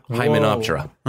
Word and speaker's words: Hymenoptera: They're what we call hymenoptera, the ants, bees, and wasps. – That's ants are related to wasps. – Yeah. – Hymenoptera.